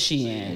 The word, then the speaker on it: Shein: So it is Shein. Shein. I've been saying it right then.